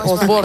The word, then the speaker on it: Osborne: lo estaba discutiendo antes porque no sabía cómo pronunciarlo. – ¿En inglés? – Sí, porque claro, yo aquí escuchaba que Osborne. – Dicen exactamente Osborne.